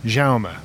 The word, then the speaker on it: Jaume: Jaume Munar? – Jaume.